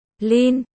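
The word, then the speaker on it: Lin (林): Lâm trong tiếng Trung đọc là Lin, Co, Cốt, Lâm mã hiệu.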